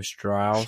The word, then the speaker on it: Strauss: Like you said, you have this change of scenes between Oppenheimer's point of view and how do you say his name? Strauss. Strauss. Yeah. – Strauss's Strauss. point of view.